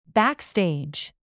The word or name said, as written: backstage